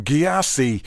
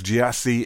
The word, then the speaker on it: Gyasi: That's a tune called Feed Your Face there from Gyasi, which I've deliberately mispronounced that, but it's G-Y-A-S-I in case you're interested. But it's from his new album, which we'll explain all now, called Pronounced Gyasi. There you go. So I suppose Gyasi is pronounced Gyasi.